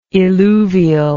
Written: illuvial

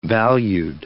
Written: valued

